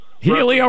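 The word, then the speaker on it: Heliot: Helio Ramos, I'm so excited. – Yeah, I never knew how to say his first name. No, you're saying it right. I'm anglicizing it.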